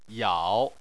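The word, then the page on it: yao: tone 3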